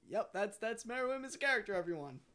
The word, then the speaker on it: Meruem: Takes off the makeup, but Miriam is. – I think from Hunter Hunter. – Meruem.